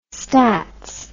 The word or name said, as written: stats